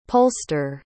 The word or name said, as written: polster